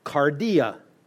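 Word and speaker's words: kardia: It's cardia. Ever heard cardiac? Everything to do with heart comes from this word. Only spell it with a K because they didn't have a hard C in Greek.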